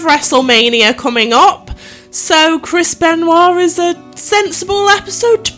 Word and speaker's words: Benoit: So today we are going to be covering the case of Chris Benoit. – Yes, Benoit.